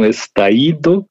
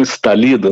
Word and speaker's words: estallido: falou assim: os chilenos eles falam estaído e não estalido, né, como nós pronunciaríamos.